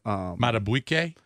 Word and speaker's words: Madubuike: Madubuike? – Yeah.